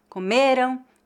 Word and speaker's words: comeram: A única diferença de pronúncia entre as palavras comeram e comerão é a sílaba tônica.